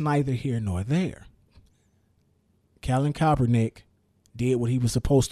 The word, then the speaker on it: Kaepernick: It's Colin Kaepernick. – It's Colin Kaepernick.